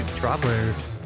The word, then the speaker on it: Droplr: Dude, Droplr, is that how you say it? That's how you pronounce it? – Yes. – Yeah, well done.